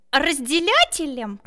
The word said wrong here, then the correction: разделителем: Не разделятелем, а разделителем.